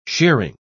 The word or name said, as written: shearing